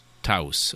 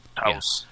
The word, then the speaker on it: Taos: Taos. Okay. – Taos. Yeah. There we go. – Thank you, Wikipedia. The Taos hum. Okay. Have you ever heard of the Taos hum? – The Taos house? – Taos house.